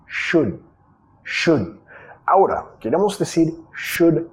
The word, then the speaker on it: should: ¿Cómo pronunciamos should? Primero tenemos sh, sh, sh, como sh. Más el sonido vocálico, acá muchos dicen should, ud, como dando un besito, no quieres. Sh, El u es un sonido que no existe en español.